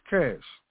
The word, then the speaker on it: CSH: Also known as CSH, And that acronym is pronounced as CASH, which stands for Combat Support Hospital.